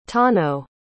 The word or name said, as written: tano